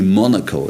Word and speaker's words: Monaco: And or Tony Monaco, that's the right pronunciation.